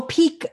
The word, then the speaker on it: Pique: You know that pick tea, P-I-Q-U-E. – Oh, peak. Oh, yeah, yeah, yeah. Okay, that's how you say it.